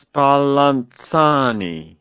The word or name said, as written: spallanzani